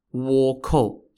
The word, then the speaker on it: Wokou: Wokou, spelt W-O-K-O-U, can mean either Japanese pirates or dwarf pirates. In Korean, it is pronounced Wegu, and in Japanese, it's pronounced Wako.